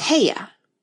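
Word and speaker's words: Haigha: His name is Heia." – He pronounced it so as to rhyme with Mayor.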